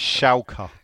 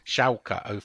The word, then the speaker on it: Schalke: What how are they pronounced? Schalke. Schalke 04.